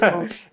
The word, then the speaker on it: moot: it's it's a bit of a mute point. – A so, moot point. A moot point. I don't know how to pronounce that word. it's moot. – it's yeah. moot. Okay.